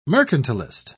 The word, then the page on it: mercantilist: British English